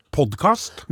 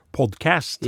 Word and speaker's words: podkast: Og det er litt artig, for jeg har lagt merke til det at det er to forskjellige skoler når det gjelder hvordan man uttaler det her podkast. Vi sier Podkast. Men så har du den yngre garde, og de sier Podcast. Yes. Ja. Mens vi sier altså podkast, da.